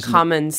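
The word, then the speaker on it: Commins: Cummins or Cummins? Am I saying that right? – Cummins, C-O. – It's Cummins, okay, because you're in west of Ireland.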